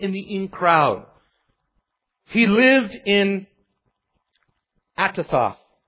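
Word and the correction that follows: Anathoth: But he lived in a small town near Jerusalem, but he wasn't involved, Anatoth, excuse me.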